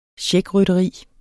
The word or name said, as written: checkrytteri